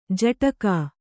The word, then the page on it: ಝಾಡಿಕೆ: jhāḍike